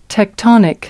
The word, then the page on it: tectonic: American English